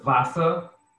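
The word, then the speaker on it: Wasser: The word Vasa is spelled with a V, with a W, sorry. In German, you spell a word with a W, but it's pronounced as a V.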